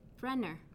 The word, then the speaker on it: Brenner: Brenner. – How do you spell that? – B-R-E-N-N-E-R.